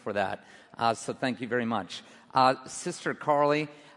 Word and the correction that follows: Kali: just, that is pronounced right, right? Kali. Sorry. Just this evening, walking around, hanging around campus, actually went to the gym today.